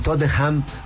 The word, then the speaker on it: Tottenham: Pero el Tottenham, porque como que no está la H al medio de la N.